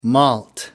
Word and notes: malt: American English

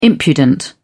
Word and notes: impudent: British English

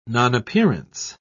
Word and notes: nonappearance: British English

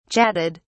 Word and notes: chatted: British English

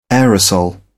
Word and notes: aerosol: British English

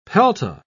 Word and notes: pelta: British English

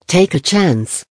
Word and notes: take a chance: American English

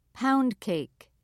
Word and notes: pound cake: British English

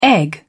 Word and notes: egg: British English

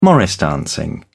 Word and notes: morris dancing: British English

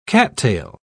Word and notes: cattail: British English

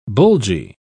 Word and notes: bulgy: British English

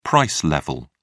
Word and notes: price level: British English

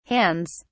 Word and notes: hands: British English

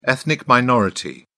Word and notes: ethnic minority: British English

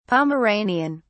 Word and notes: pomeranian: British English

